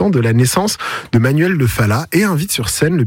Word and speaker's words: Manuel de Falla: Je me permets, on dit Manuel de Falla. – De Falla. – Faut le prononcer à l'espagnol, s'il vous plaît. – Manuel de Falla.